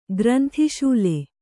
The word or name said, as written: ಗ್ರಂಥಿಶೂಲೆ